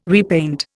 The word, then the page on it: repaint: Indian